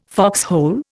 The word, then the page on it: foxhole: Indian